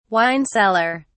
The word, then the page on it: wine cellar: British English